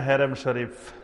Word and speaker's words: হারাম শরীফ: শায়েখ বাইতুল্লাহর নাম কি হারাম শরীফ না হেরেম শরীফ? বাইতুল্লাহর নাম হারাম শরীফ, হেরেম না, উচ্চারণ হলো হারাম শরীফ না, এটা খেয়াল রাখবেন।